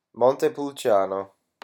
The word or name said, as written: montepulciano